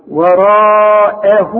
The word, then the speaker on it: وراءه: وراءه وهو الحق أعلمك إزاي تنطق وراءه إزاي ورققها راء مد شفايفك على هيئة قبلة والمد بتاعها كمان مفخم وراء أه رجع شفايفك على هيئة ابتسامة وراءه واخد بالك وهو آه الواو اهي مرققة شوف قلتي وهو إنما عند الوقف أقول إيه؟